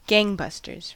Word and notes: gangbusters: American English

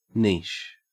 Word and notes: niche: Australian English

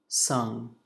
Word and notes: sung: American English

